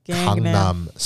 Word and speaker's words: Gangnam: I've also heard, you know, other pronunciations. It's not Gangnam Style. – Gangnam Style. – It's not Gangnam Style, okay? – Gangnam. – It's Gangnam Style.